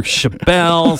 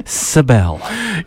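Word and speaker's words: Sczebel: Greg Sebel, and if you saw his name, you'd never know how to pronounce it. S-C-Z-E-B-E-L. I went through Chabelle, Sickazibble, Sebel.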